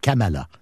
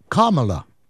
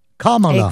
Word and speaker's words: Kamala: Ici, nous autres, on le prononce un peu en français, Valérie, en disant Kamala. C'est Kamala Harris. Mais la façon exacte de prononcer son nom, c'est Kamala. Kamala. – Exactement. – Comme une virgule en anglais, Kamala.